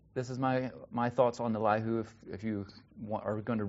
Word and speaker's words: Elihu: That's how I'm going to pronounce his name. Elihu, and he's a younger man, so he's kind of been sitting through this.